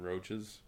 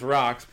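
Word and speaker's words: Roch's: They call it's called Rocks, but it's spelled ROCH. So I call them fucking roaches.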